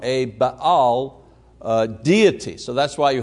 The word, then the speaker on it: Baal: Jezebel was from Tyre, and through her influence, the worship of Baal Melquart, who was the official nature god of Tyre, was being actively brought into the kingdom. Now, the word Baal, or Baal as it's pronounced, means master or possessor or husband.